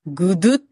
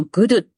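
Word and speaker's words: gudeg: で、まず一つ目がですね、ちょっとこれ日本人には発音難しいんですけれども、グドゥっていうのと、クレチェックって言います。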